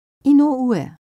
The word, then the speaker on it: Inoue: Inoue. – Wie bitte? Wie schreibt man das? – I-N-O-U-E.